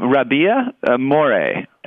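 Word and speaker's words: Rabih Mroué: weekend with the one you were talking about with the opening night performance, and how do you say he pronounces his name Rabia More, and he's actually been with us twice before, and he is a fascinating guy.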